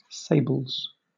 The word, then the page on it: sables: British English